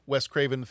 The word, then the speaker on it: Wes Craven: If you like Wes Craven movies, I think it's a, a pretty good example of Wes Craven films. Um, and uh, it's got some trademark Wes. Res, let's try saying his name correctly.